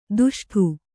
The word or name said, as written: ದುಷ್ಟು